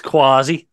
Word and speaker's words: Quashie: I think it's Quasi. I think that's how it's pronounced, Nigel Quasi.